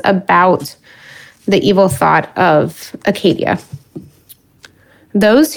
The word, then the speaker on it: acedia: So anyways, this isn't that, the start of that series, but I am talking about one of them, which is listlessness, better known as Acadia or Achadia or Acedia. I prefer the pronunciation Acadia, but so John Cashin learns about the eight evil thoughts from Evagrius, who's his elder.